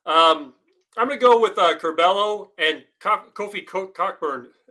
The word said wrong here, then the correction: Cockburn: I always say it wrong. Coburn at Illinois.